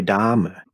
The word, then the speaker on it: Dahmer: Well, it's an H. It's it's Jeffrey Dahmer.